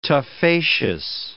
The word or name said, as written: tuffaceous